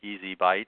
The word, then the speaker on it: ESI Bytes: And you can also remember our show on the website phonetically as Easy Bytes, which is how it sounds out of ESI information.